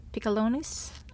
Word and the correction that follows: Piccolinos: Piccolinos. Yeah.